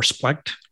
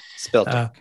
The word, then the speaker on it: Speltech: You've been part of the Exploits Aboriginal Community Group, I think one of the groups in that area, and we have Speltech or Splect, depending on your pronunciation. – Speltech, yeah.